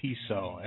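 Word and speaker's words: Tso: This sucks with a silent T in front of it. I had a friend that used to just go General T-So every time he said that.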